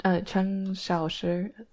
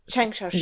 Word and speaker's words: Cheng Xiaoshi: Yeah, I was thinking about that earlier, and I was thinking, like, well, Cheng Xiao, how do you pronounce it? Cheng what? – Cheng Xiaoshi. – Cheng Xiaoshi. Yeah, yeah.